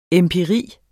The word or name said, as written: empiri